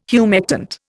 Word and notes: humectant: Indian

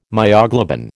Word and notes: myoglobin: American English

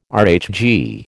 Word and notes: RHG: American English

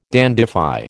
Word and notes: dandify: American English

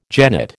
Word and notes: jennet: American English

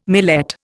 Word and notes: millet: Indian

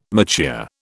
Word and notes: mature: British English

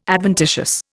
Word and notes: adventitious: Indian

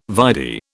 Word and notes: vide: British English

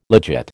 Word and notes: legit: American English

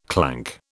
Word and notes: clank: British English

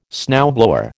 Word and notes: snowblower: American English

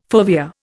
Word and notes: fovea: Indian

